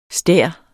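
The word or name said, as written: STÆREN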